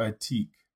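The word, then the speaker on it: Atiq: Hold on, real quick. Omar Atik. – Omar Atik. – Yes. – How is it spelled? A-O-M-A-R. – O-M-A-R-A-T-I-Q. – Okay. Atik. That sounds like it's right.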